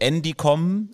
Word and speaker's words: Endicom: Und da stellt sich jetzt nochmal die Frage, wie spricht man ihn jetzt genau aus? Also es gibt offenbar auch eine andere Variante. Endicom ist, glaube ich, aber die offizielle Variante. – So stand es zumindest in der Ausspracheliste offiziell von Montel Münster.